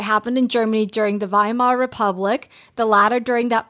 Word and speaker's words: Weimar: I now want to experience my other fascination of a historical time in Berlin, which is, I'm probably saying it wrong, Weimar. It's Weimar, Berlin. – I don't see why Weimar. That would be wrong. Weimar seems correct. – Weimar.